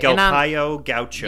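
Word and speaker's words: Galpão Gaucho: Galpao Gaucho. I don't know if I'm saying it right. – Galpao Gaucho. Yeah, G-A-L-P-A-O space G-A-U-C-H-O. All right?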